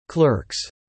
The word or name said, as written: clerks